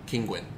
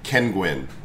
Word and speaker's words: Kinguin: Kinguin? – Kinguin. – Spell it. – King. U-I-N. – Like Kinguin. I thought it was a Kenguin, like as a penguin named Ken.